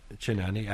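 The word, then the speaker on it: Cynane: Whom did, and I'm probably going to pronounce this name wrong too, Kinani? – Kinani. – Actually, I think in Greek it would be Kinani. – Kinani, that's right.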